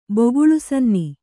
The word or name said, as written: ಬಗುಳುಸನ್ನಿ